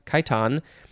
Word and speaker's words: chiton: Now we have a similar word, which is pronounced the same way, chitin or chit- chiton. Um, so it's C H I T O N. Uh, chitin and chiton are both fine to say.